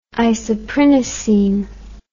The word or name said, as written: isoprinosine